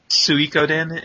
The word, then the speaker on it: Suikoden: Suikoden, it's I mean, you know, from the McDole thing you would think you know, holy shit.